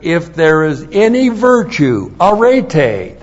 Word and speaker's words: arete: The Greek word for virtue is arete. A-R-E-T-E. long E. Arete.